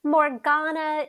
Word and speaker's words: Morgana: Morgana or Morgana, I've heard both pronounced both ways. It's Morgana, right? Correct? – Morgana is preferable, but if you say Morgana, I'm cool with it. East Coast people tend to say Morgana.